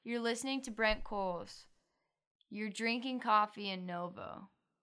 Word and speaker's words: Cowles: Brent Coles, that's how you say his name. You spell it C-O-W-L-E-S, Coles.